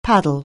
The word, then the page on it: paddle: anglais